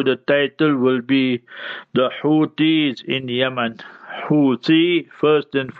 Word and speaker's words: Houthis: So the Houthis in Yemen. So Houthi will be H-O-U-T-H-I-S.